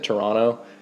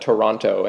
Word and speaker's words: Toronto: Because everyone outside of it will call it Toronto and always pr- pronounce the second T, um, but no one from here does.